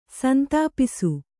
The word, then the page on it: ಸಂತಾಪಿಸು: santāpisu